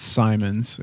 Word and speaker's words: Simons: I believe it's Simons. Yeah.